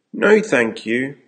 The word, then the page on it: no, thank you: American English